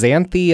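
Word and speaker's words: Xanthe: This is Xanthi Alexis.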